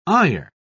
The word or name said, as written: ire